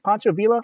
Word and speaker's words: Pancho Villa: And the sets used in Horror Express were actually from the production of Pancho Villa. – Wow. – Am I saying that right? Pancho Villa? – Yes, I believe you are.